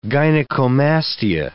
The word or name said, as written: gynecomastia